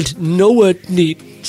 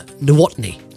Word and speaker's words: Nowotny: ECB Governing Council member Ewald Nowotny said on Friday. I don't know how to pronounce that surname. Nowotny, that's it. Nowotny.